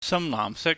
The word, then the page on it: samlaahmsik: cantonese